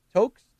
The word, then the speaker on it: toque: Well, at 2 hours and 8 minutes of episode 230, BC pronounces toques. Toques? Toques? – Toque. Toques. – When referring to the type of hat that should be pronounced toques. It is pronounced toque. It rhymes with smoke.